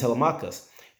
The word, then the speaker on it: Telemachus: This guy, Odysseus, goes on this long epic journey, the Odyssey, and he's got a child named, I believe you pronounce it Telemachus. That's how I pronounce it, at least. He's got a child named Telemachus.